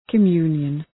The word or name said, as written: communion